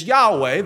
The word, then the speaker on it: Yahweh: There's no J in Hebrew. It's Yahweh. That's the proper pronunciation in English.